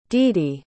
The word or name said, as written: deedy